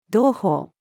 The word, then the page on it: 同胞: female